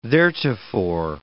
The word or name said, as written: theretofore